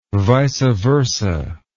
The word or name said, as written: vice versa